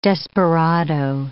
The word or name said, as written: desperado